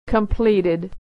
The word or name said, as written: completed